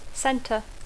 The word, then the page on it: center: English